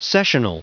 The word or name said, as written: sessional